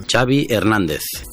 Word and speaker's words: Xavi Hernández: Xavi Hernández. Xavi like chocolate.